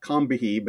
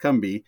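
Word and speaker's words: Combahee: It's spelled. It should be like Combihee, but the locals there pronounce it Combi.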